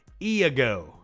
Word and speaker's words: Iago: Here's J- Iago. Iago. Iago. Lego my ego.